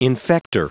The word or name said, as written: infector